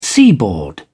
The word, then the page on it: seaboard: British English